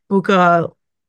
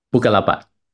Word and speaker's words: Bukalapak: Bukalapak. Bukalapak.